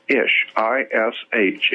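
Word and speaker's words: ishi (אִישׁ): The word man in Hebrew is ish, I-S-H. It's pronounced ishi. I-S-H is man in Hebrew.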